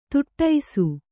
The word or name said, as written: ತುಟ್ಟೈಸು